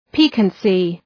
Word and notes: piquancy: ‘pi:kənsı

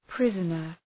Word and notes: prisoner: ‘prızənər

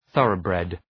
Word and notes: thoroughbred: ‘ɵʌrə,bred

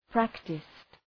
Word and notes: practised: ‘præktıst

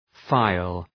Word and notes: phial: ‘faıəl

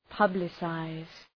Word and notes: publicize: {'pʌblı,saız}

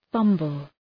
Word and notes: fumble: ‘fʌmbəl

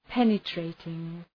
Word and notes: penetrating: ‘penə,treıtıŋ

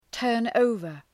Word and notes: turn over: ‘tɜ:rn,əʋvər

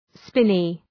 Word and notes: spinney: ‘spını

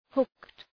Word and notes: hooked: hʋkt

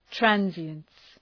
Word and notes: transience: ‘trænzıəns

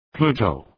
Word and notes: Pluto: {‘plu:təʋ}

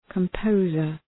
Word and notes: composer: {kəm’pəʋzər}

